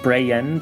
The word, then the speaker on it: Bragan: Uh, it, it's written Bragan, but it's it's pronounced Brayen because it's uh, sort of rooted in, in Old English.